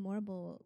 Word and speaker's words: Morrible: And I'm like, well, Madame Morrible rhymes with horrible for a reason.